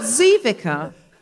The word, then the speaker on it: Ziwicka: Kristine Ziwicka. – It's nothing like what I've ever said. I know you're Kristina, but Ziwicka.